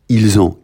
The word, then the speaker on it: ils ont: Ils ont. Can you do a little loop between they and the O? Yeah, ils ont. Dot on the T again, silent T.